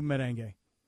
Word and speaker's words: merengue: I knew merengue. – Yeah. Look, you actually said it right.